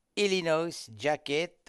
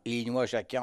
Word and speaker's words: Illinois Jacquet: Troisième séance d'enregistrement, Wynonie Harry chante au sein de la formation du grand saxophoniste ténor, applaudi à Limoges d'ailleurs, lui aussi, Illinois Jacquet ou Illinois Jacquet en fonction de la prononciation qu'on adopte.